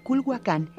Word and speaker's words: Culhuacán: De Culhuacán, que ahora se pronuncia Culhuacán, he oído hablar, David.